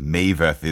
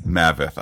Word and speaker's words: Maveth: With that, we cut to the castle where, we are reminded that Maveth is Hebrew for death. Wait a minute. Isn't that the name of the episode? – Yeah, and it's pronounced Maveth. – Maveth.